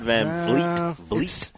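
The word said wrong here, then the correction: VanVleet: You might want to get Van Vliet right.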